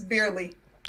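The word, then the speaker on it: Beierle: The NATC report specifically takes a close look at a 2018 shooting by Scott Paul, can you pronounce that name? Burley? Who killed two women and wounded others. – It's Burley. – Burley, okay.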